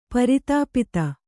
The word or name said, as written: ಪ್ರತಿಪತ್ತು